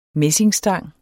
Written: messingstang

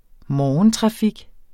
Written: morgentrafik

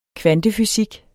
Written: kvantefysik

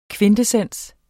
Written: kvintessens

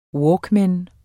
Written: walkman

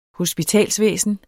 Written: hospitalsvæsen

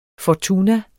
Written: Fortuna

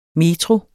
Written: METROER

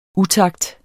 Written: utakt

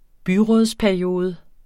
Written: byrådsperiode

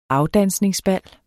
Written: AFDANSNINGSBAL

